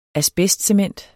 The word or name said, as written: asbestcement